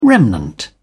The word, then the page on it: remnant: British English